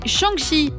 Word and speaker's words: Shang-Chi: La première sortie de la semaine, c'est Shang-Chi et la légende des 10 anneaux. J'espère que je le prononce bien. – C'est tout à fait ça.